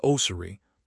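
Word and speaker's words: Oseary: Guy Oseri, pronounced O-Z-E-E-R-E, built his career on a similar principle of rapid pattern recognition.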